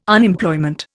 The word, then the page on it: Unemployment: Indian